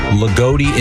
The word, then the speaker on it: Loogootee: L-O-O-G-O-O-T-E-E. – Lagodi? – Lagodi, Indiana.